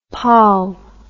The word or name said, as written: paal